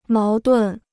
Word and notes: 矛盾: máo dùn

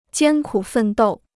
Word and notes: 艰苦奋斗: jiān kǔ fèn dòu

